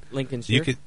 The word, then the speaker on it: Lincolnshire: I mean, Lincolnshire.